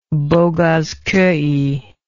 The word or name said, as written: Boghazkoy